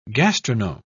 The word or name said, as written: gastronome